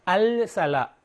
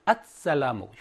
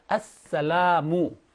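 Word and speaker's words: 앗살라무 (السلام): 알살라 알사 바쁘니까 앗살라무 혀를 고정시켜서 뒤에 오는 태양 문자 이거를 두번 발음한다 했죠. 그래서 앗살라무 자 앗살라무 그다음에 요 람은 라 플러스 알리프를 표기할 때 이렇게 단독형으로 표기하거나 또는 원래 연결형이 돼서 이렇게 표기할 수도 있죠.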